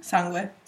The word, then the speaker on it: sangue: How do you say blood in Italian? – Sangue. – Sangue.